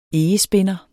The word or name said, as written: egespinder